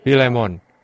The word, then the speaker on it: Filemon: Maka, Filemon, kata seseorang di belakang sana. Saya rasa itulah pengucapan yang benar untuk itu.